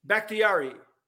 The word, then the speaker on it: Bakhtiari: Bakhtiari.